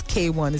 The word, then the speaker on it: KWN: And when you see her, if you don't know her, her name is spelled K-W-N and it's K1. It's pronounced K1.